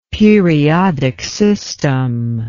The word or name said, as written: periodic system